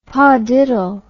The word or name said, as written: padiddle